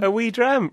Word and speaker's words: wee dram: A wee dram, really? – No, maybe later.